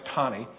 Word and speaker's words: Taney: It looks like Tanny, but it's pronounced Taney.